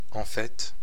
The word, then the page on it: ripe: American English; [raɪp]; /ɹaɪp/